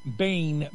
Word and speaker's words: Bain: Randy, the surname of Tennessee congressional candidate Afton Bain is pronounced like Bane, B-A-N-E, rhymes with rain and paid. Pain in the butt, Bane. Got it.